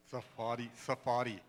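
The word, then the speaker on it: Tsarfati: Amar Sephardi. I think that's how you pronounce his last name. What is it? Amir Sephardi. Safadi. Okay. Okay, yeah, sorry. Yeah, Safadi.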